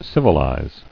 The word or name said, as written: Civilizer